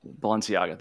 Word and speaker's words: Balenciaga: So what do you think was really going on with the Balenciaga? How do you fucking pronounce it, man? I don't know. – Balenciaga.